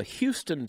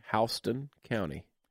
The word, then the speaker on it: Houston: The name of that county is spelled the exact same way as Houston, but that's not how you pronounce it. – Houston? – Houston County.